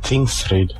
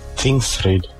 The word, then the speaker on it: Tingsryd: Wie spricht man hier Tingsdingsbums aus? – Tingsryd. – Was? Wie nochmal? – Tingsryd.